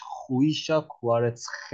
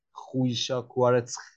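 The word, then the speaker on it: Khvicha Kvaratskhelia: درستش خویشا کوارتس که حالا احتمالا یکم سخت باشه برای تلفظ کردنش من خودم دوستمون تمرینات دیجه داشتم ولی خب الان خویشا کوارتس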